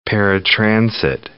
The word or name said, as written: paratransit